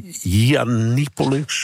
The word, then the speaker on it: Yiannopoulos: Ik heb een beetje moeten lachen eigenlijk, dat is het beste woord, over die Britse ultra-rechtse influencer Mila Yiannopoulos, zo spreek je dat.